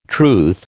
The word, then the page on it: truth: American English